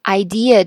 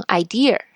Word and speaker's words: idea: 再举一个例子，很多同学都会把这个 idea 这个词读错，读成 idea，是吧？那么现在大家知道这个规律以后，就知道这个主意这个词 i d e a 没有 r，你是不可能读出 r 的这个音的，所以呢，它肯定只能是 idea，而不是 idea。